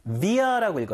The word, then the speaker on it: via: via라고 읽어요. via.